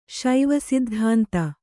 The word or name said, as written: ಶೈವಸಿದ್ಧಾಂತ